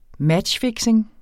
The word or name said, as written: matchfixing